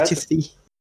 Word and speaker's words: Latifi: É, o, o, é, é, reza a lenda que a fortuna do, do, do pai do Latifi, é Latifi ou Latifi, eu nunca sei a pronúncia correta. Latifi. – É isso aí.